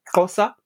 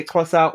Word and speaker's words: Xhosa: Xhosa. Mm-hmm. Well, it's pronounced different. Like, some people might say Xhosa or Xhosa. I like doing the click because it's a vocal flex, so why not?